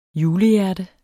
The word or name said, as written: julehjerte